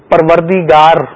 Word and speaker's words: پروردگار: اب اس جو لفظ میں پڑھنے لگا اس کے تلفظ میں کبھی اختلاف بھی ہو جاتا ہے, تب پروردگار یعنی رب تعالی کے لیے بولا جاتا ہے, تو پروردیگار لغت میں پروردیگار آیا ہے.